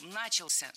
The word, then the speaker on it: начался: Много раз прозвучал глагол «начался», но это неверно. Правильный вариант – «начался», только так. Ударение в этом слове падает на возвратную часть «ся».